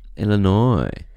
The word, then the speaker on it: Illinois: But I think there's a few Illinois. – Of them. – Illinois. It's one of those ones that doesn't look like how it's spelled.